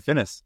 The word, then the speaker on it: Finnäs: Is it good pronounce? – Yeah, it's pretty good. Finnäs.